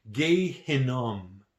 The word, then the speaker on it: Gehinnom: In Hebrew, the valley of Hinnom is pronounced Gehinnom, from which the name Gehenna comes from.